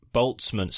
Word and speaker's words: Boltzmann: Boltzmann.